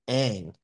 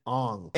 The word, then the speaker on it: Aang: Like the main character's name in Avatar is Aang, but in the movie they pronounce it Ong.